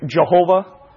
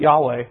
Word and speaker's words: Yahweh: People pronounce it different ways. Jehovah, right, with the J sound, or Yehovah, or Yahweh, or Yahweh. I don't know exactly 100% sure how to pronounce it, so I'm just going to ask you to excuse me, but I'm going to pronounce it Yahweh,